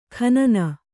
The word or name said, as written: ಖನನ